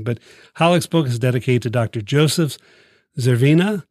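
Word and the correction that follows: Zvěřina: Zverina, 1913 to 90.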